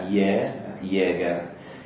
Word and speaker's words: Jäger: Allora, si scrive Jäger con la J, J-A-G-E-R, questo è il cognome, J-A-G-E-R con la diesi sulla A, che in tedesco diventa Jäger, Willis Jäger.